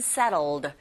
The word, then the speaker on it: settled: Where to met이 아니라 where two met and settled. Settled로 발음할 수도 있지만 t 사운드가 네, 바로 모음 사이에서 으, 르에 가까운 소리로 나기 때문에 settled 이렇게 발음해 주시면 돼요.